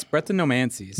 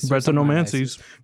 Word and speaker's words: Brettanomyces: How do I pronounce this word? – I think it's Brettanomyces. – Brettanomyces.